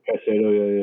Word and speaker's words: Caicedo: And you look at, like, for example, Brighton with what's the center mid, Caicedo? How do you pronounce his? – Caicedo, yeah.